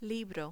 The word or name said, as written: libro